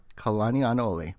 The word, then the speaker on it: Kalanianaole: It's pronounced just like it's spelled, Kalanianaole.